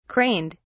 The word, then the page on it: craned: British English